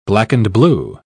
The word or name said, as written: black-and-blue